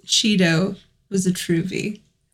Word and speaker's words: trouvaille: So it's spelled T-R-O-U-V-A-I-L-L-E. So it looks like trouvaille, but pronounced trouvé.